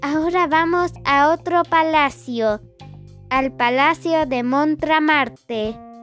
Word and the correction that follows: Montmartre: Si es que así se pronuncia. Así que vayamos. Antes de decirles datos de Montmartre, que es así como se pronuncia, es la primera corrección que voy a hacer porque voy a hacer tres correcciones.